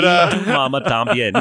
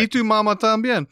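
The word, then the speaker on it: Y tu mamá también: But E tu Mama Tambien. No, you're saying it right. – E tu Mama Tambien.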